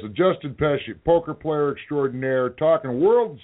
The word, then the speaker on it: Pechie: P-E-C-H-I-E, is it? – Yeah, and I asked his publicist, I'm like, so how does he say it like Pesci? She's like, yep, just like Joe Pesci.